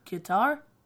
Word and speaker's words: Qatar: Here's how you're supposed to say it. Well. That's not going to work. Qatar. – Qatar. – Qatar. – Qatar. – Qatar. – But Qatar. It's spelled Q-A-T-A-R. And it's called Qatar.